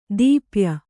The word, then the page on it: ದೀಪ್ಯ: dīpya